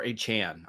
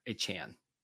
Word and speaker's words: Achane: Devin Achane, or Achane, I believe is how you say it. Achane.